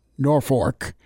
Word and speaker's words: Norfolk: So you got to make sure you pronounce it correctly, Norfolk.